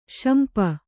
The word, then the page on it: ಸೊಂಪು: sompu